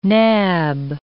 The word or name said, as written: nab